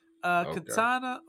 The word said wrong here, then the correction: Katara: or is it? Did I say it right? Katana. – Katara.